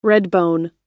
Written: redbone

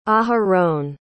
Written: aharon

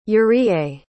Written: yuriye

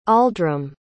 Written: aldram

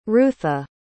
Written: rutha

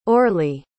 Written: orli

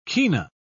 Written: kina

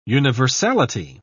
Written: universality

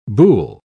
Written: buhl

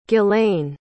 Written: ghislaine

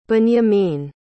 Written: binyamin

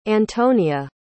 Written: antonia

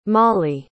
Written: mollie

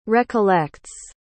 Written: recollects

